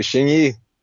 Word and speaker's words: Xing Yi: Xing Yi.